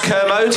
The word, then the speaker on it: Kermode: Watch your back, my own commode. We're coming for you. – It's, yeah. It's pronounced Kermode.